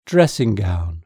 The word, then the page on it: dressing gown: British English